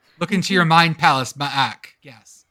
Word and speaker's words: MAAC: Is that how you pronounce that conference? The MAAC? – Yeah, it is now.